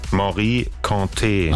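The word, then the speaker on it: Mory Kanté: Wie wird's ausgesprochen? – Morikante. – Ach so, guck mal. Es war also falsch.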